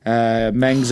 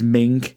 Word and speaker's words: Meng: Meng's accompanied by Kevin Sullivan, or the Taskmaster. Is it the same person? We're not sure. Is it just me, or do the comms keep pronouncing Meng as Ming? – Yeah, they do.